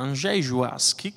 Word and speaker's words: Andrzej Żuławski: Allora, Andrzej Żuławski, questa è proprio la pronuncia polacca corretta, ho scelto questo epiteto di Don Chisciotte di Leopoli.